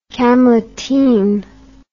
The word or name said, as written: camleteen